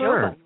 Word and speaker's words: Jova: And that Hurricane Jova, how do you say that, by the way? – I think it's Jova. – Yes, is it Jova? – It's Jova. You're exactly right.